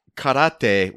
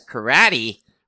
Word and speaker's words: karate: Karate, which people mispronounce as karate, which is, you know, give them a break.